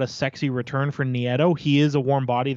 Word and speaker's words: Nieto: It's pronounced, if I remember correctly, it's pronounced Long Beach native Matt Nieto.